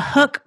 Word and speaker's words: Huk: Hey, y'all, if you spend any time at all in the outdoors, you've probably noticed the Hook Performance Fishing brand of apparel. It's spelled H-U-K.